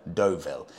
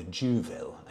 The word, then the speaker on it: Deauville: What was I calling it? The Juville, I think. Semi awkwardly pronouncing where I knew I was wrong.